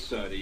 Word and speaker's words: Cerny: It's Cerny. – Future Jesus. – Yeah. It is Cerny, yeah.